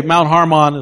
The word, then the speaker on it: Hermon: Okay, and by the way, Mount Hermon is how they pronounce it in Israel. You say, I thought it was Hermon. No. In Israel, they pronounce it that way.